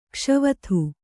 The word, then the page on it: ಘೋಷವತ್ತು: ghōṣavattu